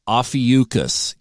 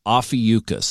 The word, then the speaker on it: Ophiuchus: Ophiuchus is how you pronounce the 13th constellation, if you will. Ophiuchus.